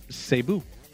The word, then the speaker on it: Cebu: Cebu. I just wondered whether I was saying that correctly. – Yeah, you got it.